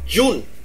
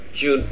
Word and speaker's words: June: Còn cái này nó có một cái ngắt chữ D ở phía trước, nó là June. Thực ra thì em thử phát âm chữ June này lại cho anh nghe nha. – June.